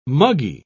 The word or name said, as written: muggy